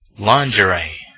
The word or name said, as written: lingerie